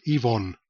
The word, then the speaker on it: Yvonne: I think her name is pronounced Evan, and Pamela Queeley. Well, I did see a YouTube video, and Pamela did refer to her sister as Yvonne. So that's probably fair enough. She'd know better than I do.